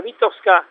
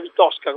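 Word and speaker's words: Vitovska: La Vitosca si pronuncia così, non si pronuncia Vitosca come mi hanno detto, mi hanno corretto più volte.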